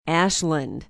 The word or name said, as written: Ashland